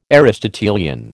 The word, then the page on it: Aristotelian: American English